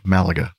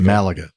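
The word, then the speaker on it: Málaga: Malaga. – Thank you. That's another pronunciation of that city. Probably the proper one. Thank you. What did you say again? – Malaga. – Malaga.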